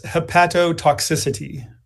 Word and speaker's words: hepatotoxicity: Help me pronounce that. What is that word? – I'll pronounce it as hepatotoxicity. – Oh, I like that. Hepatotoxicity.